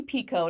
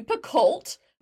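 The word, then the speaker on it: Picoult: And she introduces the book and she's like, "I'm Jodi Picco." And I was like, "I've been calling you Picolt for a decade. – Don't say the L and the T."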